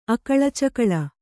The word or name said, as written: ಅಕಳಚಕಳ